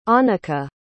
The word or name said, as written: anica